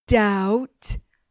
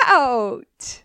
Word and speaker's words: doubt: About, doubt. Out, shout. Silas, the silent letter lamb. The letter B is silent in my name, lamb, and in the word doubt.